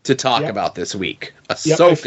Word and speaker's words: Ahsoka: I was doing the bit where it was Oshkosh by gosh, but people weren't taking that seriously. So I learned how to say it to Ahsoka. – And I was saying it like Asuka, the World Wrestling Entertainment wrestler.